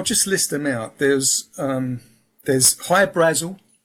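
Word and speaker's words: Hy-Brasil: But it's High Brazil because that's how the Irish would pronounce it, because it's Okay.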